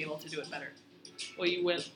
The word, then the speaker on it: hoyuelo: H-O-Y-U-E-L-O. Oyuelo. – Yeah. – Did I say it correctly? – You pronounce it like as if it was two L's. Oyuelo.